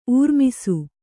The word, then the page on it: ಊರ್ಮಿಸು: Kannada